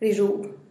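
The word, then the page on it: rizhub: Teotitlán del Valle Zapotec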